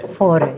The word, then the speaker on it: Fårö: Fårö? Okay. – Fårö, yes.